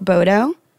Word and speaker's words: Baudot: And my Instagram is Brooke Bodo. My last name is spelled B-A-U-D-O-T, but you can add that in the comments after.